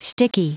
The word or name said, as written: sticky